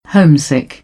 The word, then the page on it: homesick: British English; əʊ